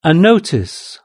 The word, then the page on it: a notice: British English